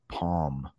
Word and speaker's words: palm: Hopefully as a teacher, I do sometimes, but that part of your hand is called the palm. The palm. The L is not pronounced.